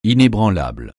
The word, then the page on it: inébranlable: francais